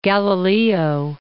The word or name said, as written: Galileo